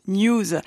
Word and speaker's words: N3W5: Elle s'écrit N3W5, mais se prononce News.